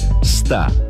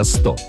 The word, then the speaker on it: 150-летию: Очень многие люди говорят «ста» вместо «сто». Конечно, ударение в этом слове падает на 7-й слог – к 150-летию. Однако не стоит забывать, что после «т» мы говорим не «а», а четкое такое «о».